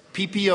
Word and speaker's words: Pee Pee: Now, there's stories behind those names, and maybe it's just my twisted nature, but I had to find out about that PP Ohio deal. It's spelled P-E-E-P-E-E.